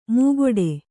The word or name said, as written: ಮೂಗೊಡೆ